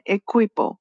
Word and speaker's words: equipo: So the U is not pronounced. Whenever we have a, consonant, vowel, a consonant U and then a vowel, uh, the U is not pronounced. Okay? Equipo.